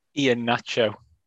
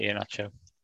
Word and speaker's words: Iheanacho: Nobody watching Iheanacho. Is it Iheanacho? Am I saying it right? – Iheanacho. – That's close enough. And he just passed it into net. It was just exactly the same goal. – Yeah, Iheanacho.